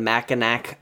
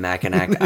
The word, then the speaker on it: Mackinac: We were up in Mackinac Island for vacation. Of course, back then we pronounced it as it should. – The Mac. – The PC Patrol dropped the hard K at the end.